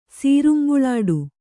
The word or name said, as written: ಸೀರುಂಬುಳಾಡು